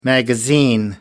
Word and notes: magazine: American English

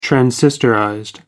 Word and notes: transistorized: American English

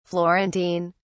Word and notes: florentine: British English

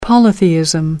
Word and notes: polytheism: American English

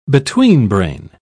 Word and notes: betweenbrain: British English

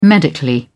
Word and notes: medically: British English

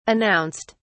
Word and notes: announced: British English

